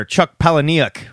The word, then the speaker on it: Chuck Palahniuk: In indie news, writer Chuck Palahniuk revealed, and that is how you say his name, revealed that he'll be writing a graphic novel sequel to Fight Club.